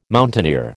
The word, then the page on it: mountaineer: American English